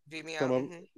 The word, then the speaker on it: Vimeo: Am I pronouncing that right? – Yes, Vimeo.